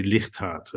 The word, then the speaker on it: Ligthart: Well, it's a Dutch name. So you would say Ligthart, Theo.